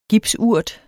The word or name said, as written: gipsurt